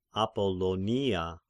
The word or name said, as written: Apollonia